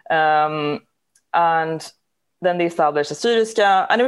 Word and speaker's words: Assyriska: I mean, so it's pronounced Assyriska because an Assyrian person is Assyrisk in Swedish.